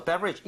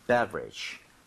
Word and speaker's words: beverage: So, beverage. 이것도 발음을 한번 해볼게요. 앞에 B, E 쪽에 강세를 주고 따라해 주세요. Beverage.